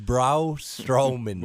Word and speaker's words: Braun Strowman: It's Braun Strowman.